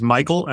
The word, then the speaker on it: Michael: Well, my personal email is Michael, M-I-C-H-A-E-L, dotter period. My last name, which is spelled just like diet soda, D-I-E-T, and helm, like the helm of a ship.